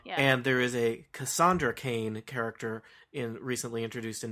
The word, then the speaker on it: Kane: And in fact, Kane rhymes with Wayne, mm-hmm.